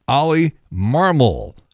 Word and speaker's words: Marmol: But for some reason, everybody is mispronouncing Ollie Marmol's name. They keep wanting to put the accent on the second syllable instead of the first, and it's wrong.